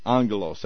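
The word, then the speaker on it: angelos (ἄγγελος): It's two G's in the Greek, A-G-G, but you pronounce it A-N-G, angelos.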